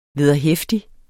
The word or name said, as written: VEDERHEFTIG